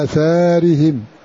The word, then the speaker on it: آثارهم: فهذه الألف محذوفة رسما في المصاحف، ولم يقرأ أحد بحذفها في اللفظ لفظا، الكل ينطقها فهم على آثارهم، ولكنها رسما في المصحف تجدها محذوفة ألف صغيرة عند كتاب المصاحف، عند علماء الرسم.